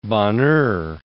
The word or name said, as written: Bonheur